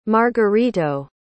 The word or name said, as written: margarito